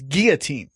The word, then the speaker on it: guillotine: I go, it's guillotine.